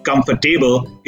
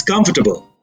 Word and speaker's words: comfortable: So that's something that really irks me, and I'm just itching to correct them and tell them it's not veggie table, but vegetable. It's not comfortable, it's comfortable. I think the reason people make this pronunciation boo-boo is because they tend to pronounce every syllable the way it's spelled.